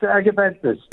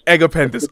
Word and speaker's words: Agapanthus: Is it Agapanthus? – It's Agapanthus. – Agapanthus. Okay, perfect. Just needed to get the pronunciation right.